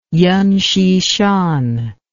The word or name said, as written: Yen Hsi-shan